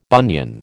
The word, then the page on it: bunion: American English